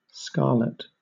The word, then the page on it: scarlet: British English